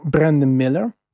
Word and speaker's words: Brandon Miller: Maar eh, de Hornets kiezen voor Brandon Miller.